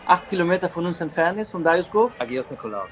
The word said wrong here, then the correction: Nikolaos: Agios ah, Nikolaos, Nikolaos.